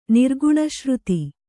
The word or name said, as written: ನಿರ್ಗುಣಶ್ರುತಿ